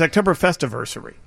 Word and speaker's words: Oktoberfestiversary: Beguile Brewing Company and Dovetail Brewery, the third annual Oktoberfestversary. Mm-hmm. Right? It's Oktoberfestiversary. – Yeah. – I'm missing an is sound. I'm missing an entire syllable right there.